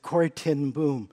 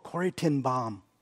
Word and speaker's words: Corrie ten Boom: I said, "Corrie ten Boom." They said, "Oh, Corrie ten Boom." I realized that my whole life I'd mispronounced her name, but I'm going to continue doing it because it's the way I know.